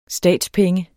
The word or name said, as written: statspenge